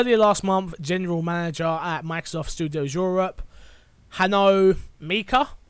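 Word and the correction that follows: Hanno Lemke: I might have said that wrong, Mecca? Lemeca? – Hanno Lemeca.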